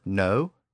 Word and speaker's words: know: You notice that little K at the beginning of the word know, K-N-O-W? Well, it's there because it was once pronounced.